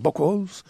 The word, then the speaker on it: Buchholz: Herman Rarebell y Francis Buchholz, que parece que se dice así, Buchholz, en alemán.